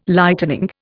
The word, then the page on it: Lightening: Indian